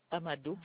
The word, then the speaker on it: Amado: Oui, alors, mon nom, Belobo Amado. – Belobo, comme ça s'entend. Hein. – Belobo. Oui, Belobo, B-E-L-O-B-O, Belobo. – Okay. – Amado, plus loin, A-M-A-D-O, comme ça se prononce aussi.